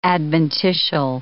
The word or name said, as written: adventitial